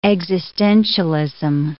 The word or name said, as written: existentialism